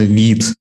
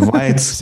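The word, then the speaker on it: Vite: А, произносится именно вид. Это не вайт, это не вите, это не витё, там, не знаю, что-нибудь такое, это именно вид.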